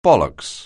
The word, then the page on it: bollocks: anglais